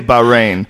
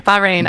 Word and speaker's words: Bahrain: You gotta say Bahrain. – Bahrain.